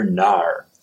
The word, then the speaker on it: Gnar: And I'm drinking their NAR. And that's spelled G-N-A-R, like gnarly.